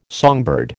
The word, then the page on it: songbird: American English